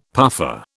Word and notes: puffer: British English

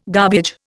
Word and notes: garbage: Indian

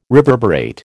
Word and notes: reverberate: American English

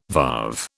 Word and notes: varve: British English

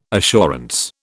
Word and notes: assurance: British English

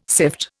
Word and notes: sift: Indian

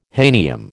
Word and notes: hahnium: American English

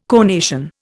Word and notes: conation: Indian

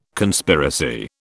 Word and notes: Conspiracy: British English

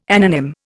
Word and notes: anonym: Indian

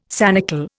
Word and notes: sanicle: Indian

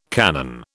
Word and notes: cannon: British English

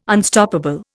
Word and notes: Unstoppable: Indian

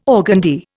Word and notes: organdie: Indian

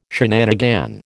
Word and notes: shenanigan: American English

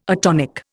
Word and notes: atonic: Indian

